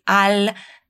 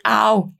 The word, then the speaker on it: al: Al mese, atenção na pronúncia desse al. Nada de al, é al. A língua sobe lá no céu da boca e desce para pronunciar esse L.